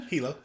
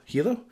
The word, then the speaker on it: Hilo: Hilo. – Hilo? – Yeah.